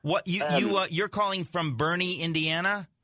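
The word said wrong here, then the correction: Berne: Well, it's actually pronounced Burn.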